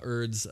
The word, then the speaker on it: Urd: Is that spelled like herb? Like Urd. U-R-D. – So no Urd. No silent H on that bad boy? – No. – If we were British, we would say herd. – No. – I'm just kidding. – No, it's just Urd.